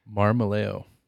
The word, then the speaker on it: Marmolejo: Marmaleo.